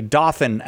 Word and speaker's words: Dothan: I'm going to say Dothan, Alabama, listening on the Catholic Answers Live app. Does that sound at all like the way you pronounce it, Tommy? – Yes.